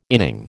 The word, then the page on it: inning: American English